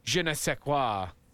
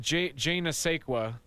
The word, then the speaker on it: je ne sais quoi: Hang on, let me pronounce that correctly. The je ne sais quoi. – Yep. Oh, there you go. Spot on. – Je ne sais quoi. Is that it? – Yeah, something like that.